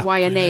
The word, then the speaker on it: Waianae: Waianae. Yeah. Thank you. That's it. It's Waianae. – Waianae. And this is one of those times when the W is not pronounced like a V.